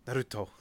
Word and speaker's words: Naruto: and half of the cast does not give a shit. So everyone calls the main character Naruto, except for Sasuke's voice actor, who's like, Naruto. Like, every time, without fail. Says it like does the softened pronunciation every time, Naruto, and it's like, geez, it's very funny listening to Sasuke do that and nobody else in the cast.